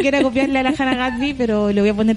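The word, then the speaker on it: Gadsby: Tú tampoco puedes pronunciar Me cuesta caleta decir Gatsby. – Es como el gran Gatsby. – Oye, qué fuerte Hannah Gatsby.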